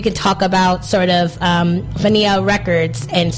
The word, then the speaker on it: Fania: The Fania, am I saying that right? – Mm-hmm. Fania.